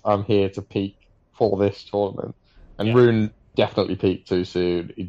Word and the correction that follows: Rune: Have to pull you up on the pronunciation. It's Runa. – Runa. Sorry. Right. Runa.